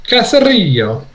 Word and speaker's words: Caserio: So the general manager from the Texans, Nick Caserio, or Caserio, I'm not sure how it's pronounced. – Caserio.